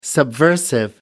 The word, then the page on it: subversive: American English